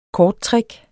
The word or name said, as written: korttrick